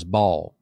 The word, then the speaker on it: Baal: And if you know the pronunciation of the real pronunciation, our English Southern, ever how we do it, we say Baal. Mm-hmm. The real pronunciation is Baal. That's how you say it. It's called Baal. B-A-A-L, Baal.